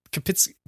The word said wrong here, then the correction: Poughkeepsie: Oh, I can't pronounce it. P- Poughkeepsie? Poughkeepsie.